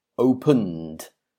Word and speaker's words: opened: And finally, open, well, this finishes in a N sound, an N sound. So again, you do not add an extra syllable, and it's pronounced opened, opened.